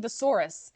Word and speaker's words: tsouris: And then he says, and frankly, I don't need thesaurus. And luckily I have captions. So I saw that it's spelled T-S-O-U-R-I-S. So I looked that up and that means aggravating trouble. – And not S-A-U-R-U-S, like Tyrannosaurus.